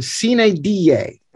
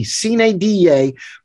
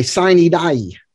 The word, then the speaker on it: sine die: And that is the phrase, it's pronounced in Latin, sine die, that is S-I-N-E space D-I-E, sine die, but you often hear it pronounced in English by people who know nothing about Latin. They don't even probably know it's Latin. They say sine die.